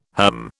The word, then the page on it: herm: British English